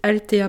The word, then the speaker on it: Althea: C'est le site Altea Provence. Ça s'écrit A-L-T-H-E-A, espace Provence.